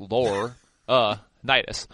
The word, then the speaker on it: Laurinaitis: It was L-O-R-E in capital letters, lore, Nitis.